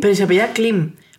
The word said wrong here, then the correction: Klimt: No, no, es Klimt.